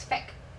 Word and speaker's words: feck: How fuck there is feck. – Feck. – It's actually like F-E-C-K. Like, it's pronounced that way.